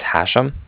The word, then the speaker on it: Hashem: My name is Hashem. – How do you spell that, sir? – That's H-A-S-H-E-M, as in mother at the end.